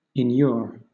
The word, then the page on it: inure: British English